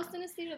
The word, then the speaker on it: synesthesia: synesthesia. I didn't say that. – Oh, synesthesia.